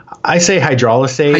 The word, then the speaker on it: hydrolysate: We're really talking about, you know, the emulsions versus the, how do you say it, hydrostate? – I say hydrolysate.